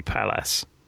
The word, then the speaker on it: Pallas: Was it a DS23 Palais or Palace? Never sure how to pronounce that. – No, it's got to be Palace.